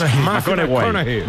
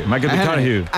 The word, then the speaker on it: McConaughey: ¿Matthew McConaughey? – Sí. sí, sí, sí. – Hay que ir corrigiendo la pronunciación. – McConaughey. – McConaughey.